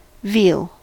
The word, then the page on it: veal: American English